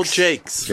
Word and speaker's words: Jacquers: How do you pronounce thank that? J-A-C-Q-U-E-R-S. Phil Jakes.